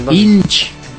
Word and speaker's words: Inch: Lo dijiste mal, otra vez. – Nine. Itch. – No es Itch. – No es Itch. – No es Itch. – ¿Cómo se pronuncia? – Inch. – Inch. – Inch. – Nine Inch Nails.